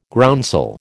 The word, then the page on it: groundsel: American English